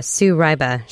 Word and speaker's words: Sue Ryba: Well, we're talking with Sue Ryba.